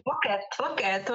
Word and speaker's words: Loket: Loket, well, yeah, it's beautiful.